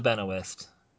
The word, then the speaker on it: Benoist: And she tied with, and I always mispronounce her last name, so get Melissa, ready for it. – Okay, Melissa Benoist.